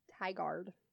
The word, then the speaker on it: Tigard: He informed me that we are saying it right. It is Tigard. It's Tigard. Tigard.